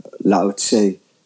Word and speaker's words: Lao Tse: Zeg ik het goed, Lao Tse? – En er wordt op verschillende manieren uitgesproken. Lao Tse is eh, zoals ik het in eerste instantie eh, leerde.